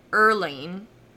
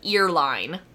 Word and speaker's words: Earlene: I really had to, like, Google how to say her name, you guys, because it looks like earline, like the line of your ear, earline, but it's Earlene, sorry, Earlene Clark.